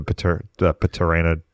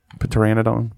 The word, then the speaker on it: Pteranodon: Yeah, she's the Pteranodon. – Pteranodon. – The P is silent.